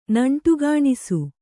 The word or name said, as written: ನಂಟುಗಾಣಿಸು